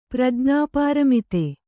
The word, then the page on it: ಪ್ರಜ್ಞಾಪಾರಮಿತೆ: kannada